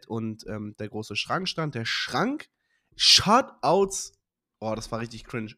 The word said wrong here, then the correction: Shoutout: Shoutout an Fendi. Ich habe Shoutout falsch gesagt. Shoutout.